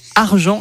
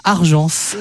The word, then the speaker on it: argent: Et je note que vous dites argent et pas argence. – Oui, moi j'ai dit argent. Écoutez, après ça dépend. Il y en a qui prononcent le S ou pas. – Moi je fais comme vous, je ne prononce pas le S. Donc je dis argent aussi.